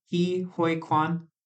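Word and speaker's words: Ke Huy Quan: Is it Ki Hoi Kwan? Ki Hoi Kwan? – Yep, you got it.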